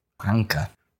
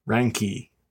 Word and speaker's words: Ranke: Ranke. – Ranke.